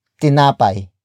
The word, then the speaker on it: tinapay: and that is tinapay. Tinapay. Tinapay. Tinapay is spelled as T-I-N-A-P-A-Y.